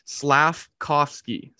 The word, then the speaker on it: Slafkovsky: Slafkovsky.